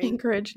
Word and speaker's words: Anchorage: Anchorage. No, no, that's correct.